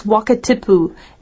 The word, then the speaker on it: Wakatipu: Okay, then lake's name is Wakatipu, and that's a Maori name, the indigenous people of New Zealand.